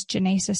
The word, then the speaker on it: Jenasa: My name is Janasa Staples. It's spelled J-E-N-A-S-A Staples.